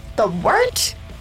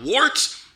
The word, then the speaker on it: wort: The Wort? Yeah, okay, I'll do that again. This is The Wort on Govs Radio. – It is The Wort, not wart, not fort, not short, The Wort.